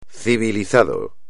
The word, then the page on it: civilizado: espagnol